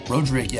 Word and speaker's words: Rodrigue: Roderick, yeah. So it's pronounced differently in kind of different areas of the state.